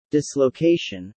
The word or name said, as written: dislocation